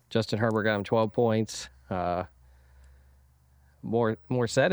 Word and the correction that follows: Mostert: is that how you say his name? They said it so much during the Browns game, it made me Mostert. – Sick. Mostert, yeah. That's it.